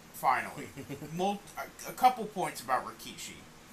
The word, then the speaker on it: Rikishi: It's Rikishi.